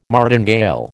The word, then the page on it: martingale: American English